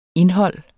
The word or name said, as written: indhold